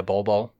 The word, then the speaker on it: Bol Bol: Yeah, Bol Bol.